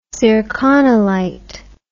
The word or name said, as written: zirconolite